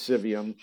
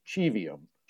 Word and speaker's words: Civium: So we pronounce our name as Civium, which is actually kind of a anglicization, if you want to say, of it, so that we can help draw that connection to those words. Although my ordained clients, who are better versed in Latin than I, like to point out that the proper Latin pronunciation should be Civium.